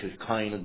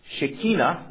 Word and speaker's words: Shekinah: Now, firstly, Shekinah, not Shekinah, it's a mispronunciation, comes from a family of words that has a very wide range of meanings.